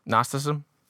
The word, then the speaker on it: Gnosticism: Gnosticism? – Yeah. The G is silent.